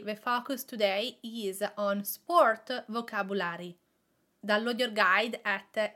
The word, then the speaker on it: sport: Today we are talking about sport. The way that we pronounce this word, the Italian way, is sport. Sport. It is an O that is more open. Sport.